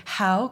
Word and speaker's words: Howe: On prononce notre nom de famille Howe, comme « How are you ?» Il y a une « et » sur le « sur ».